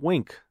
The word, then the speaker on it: Winc: Club W, it's now called Wink. That's spelled W-I-N-C. It's a new name, new look, but the same basic service. Wink works directly with winemakers and growers from all over the world to create delicious wine and deliver it right to your door.